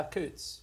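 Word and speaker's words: Coutts: So anyway, he had bank accounts at, I'm probably going to mispronounce this, Coots? – Yeah, Coots.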